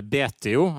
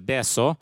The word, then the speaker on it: Betio: Så den største byen, den tror den heter Betio, heter Beso.